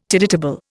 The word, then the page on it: tide table: Indian